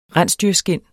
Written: rensdyrskind

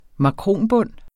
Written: makronbund

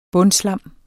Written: bundslam

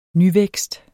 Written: nyvækst

